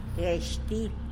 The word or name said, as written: gyesh til